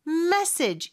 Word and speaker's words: message: 메시지는 message 이름절의 강세가 있고요.